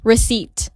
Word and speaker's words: receipt: So like kebanyakan orang pada ngomong kayak P-nya dimedokin. – Receipt. – Ya tahu lah orang Indonesia ya, semuanya dimedok-medokin. So like the correct way for you to actually pronounce it is receipt. – Oh. – Receipt. Langsung jump into T-nya.